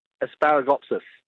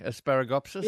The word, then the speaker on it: Asparagopsis: Asparagopsis. – Asparagopsis, as in asparagus, yep. Sort of, eh? Asparagopsis? – Yeah, that would be the best way to pronounce it, yep.